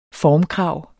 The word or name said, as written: formkrav